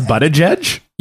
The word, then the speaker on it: Buttigieg: Is this actually pronounced Buttigieg? – Yeah.